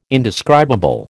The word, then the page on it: indescribable: American English